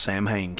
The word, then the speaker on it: Samhain: And by the way, it's pronounced Samhain, kids. That's right out of his mouth.